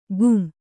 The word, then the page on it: ಘುಂ: ghum